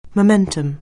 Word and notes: momentum: anglais

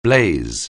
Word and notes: blaze: anglais

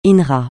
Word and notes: INRA: francais

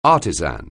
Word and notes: artisan: anglais

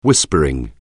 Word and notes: whispering: anglais

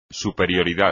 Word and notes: superioridad: espagnol

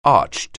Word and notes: arched: anglais

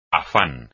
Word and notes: afán: espagnol

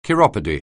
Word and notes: chiropody: anglais

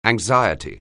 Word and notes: anxiety: anglais

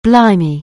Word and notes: blimey: anglais

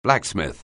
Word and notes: blacksmith: anglais

